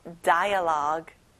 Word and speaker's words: dialogue: 끝에 U 발음은 안 나죠? dialogue.